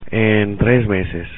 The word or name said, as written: en 3 meses